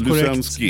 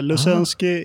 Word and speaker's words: Lusensky: Uttalar jag ditt efternamn rätt? – Helt Lusensky. Korrekt. Ibland blir det Lusensky och Luseng och sådär. Lusensky det är 5 stjärnor på det.